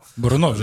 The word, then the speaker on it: Бурунов: Потому что Бурунов не выглядит человек, который я разведусь. Бурунов, э, Бурунов, Бурунов, да, да. – Бурунов же, да, я научился правильно говорить, правильно.